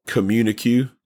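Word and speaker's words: Communiqué: It was called Communique. I'm sorry, that's how they pronounce it.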